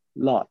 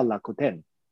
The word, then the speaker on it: Rakuten: 它的英文呢，其实叫做 Rakuten，它不是叫 lot，所以它叫 Rakuten。那其实从英文来看，你就会发现，哎，它原来是两家不同的公司。所以如果讲到 Rakuten，就是日本的乐天，我不晓得依依知不知道它在日本是什么样的一个企业吗？